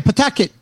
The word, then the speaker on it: Pawtucket: Uh, Aris Mercado, desde Pawtucket, Rhode Island. Am I pronouncing that right? Yes, you got it right. Pawtucket.